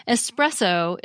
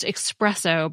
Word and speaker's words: espresso: Espresso is pronounced expresso by many people, even though there's no X in the spelling. This pronunciation probably arose by analogy with the word express.